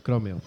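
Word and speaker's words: Chromeo: Eh, pues ya, nos vamos, los dejamos con Momas Boy de Cromeo o Cromeo, ¿cómo se dice? ¿Cromeo? Cromeo.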